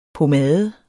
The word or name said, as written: POMADER